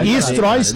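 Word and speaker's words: Streusel: Então é o Stroys que se diz, mas realmente se escreve muito diferente.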